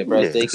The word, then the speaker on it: Brazdeikis: Brazdeikis.